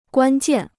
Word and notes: 关键: guān jiàn

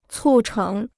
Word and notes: 促成: cù chéng

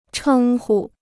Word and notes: 称呼: chēng hu

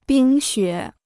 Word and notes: 冰雪: bīng xuě

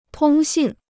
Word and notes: 通信: tōng xìn

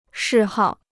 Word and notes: 嗜好: shì hào

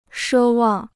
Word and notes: 奢望: shē wàng

